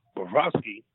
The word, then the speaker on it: Bobrovsky: Yeah, and the other thing too, Will, is that, you know, we couldn't throw major money at Sergei Bobrovsky. I'm pretty sure I said his name wrong, but you said it right.